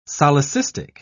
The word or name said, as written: solecistic